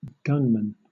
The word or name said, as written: gunmen